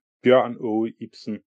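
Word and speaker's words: Bjørn Ibsen: I'm gonna do is when I come back to edit this, I am now gonna edit in saying it properly. So Ernst Trier Mørk, Bjørn O. Ibsen. – Okay, so that's how it should be said.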